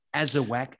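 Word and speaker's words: Azawakh: So that's the Azawak. And for those that want to know, it does have a silent H at the end. – Yes, a silent H. Yes. – The H is for hound.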